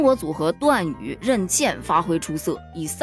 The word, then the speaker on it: 任茜: 由于这个字呢是一个多音字，在她首次亮相国际赛场上的时候，这个字读成茜，所以呢就一直延续下来读任茜。